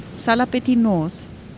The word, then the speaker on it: Salapetinos: Salapetinos. – Das heißt, dieses Betonungszeichen ist auf dem O? – Ist auf dem U.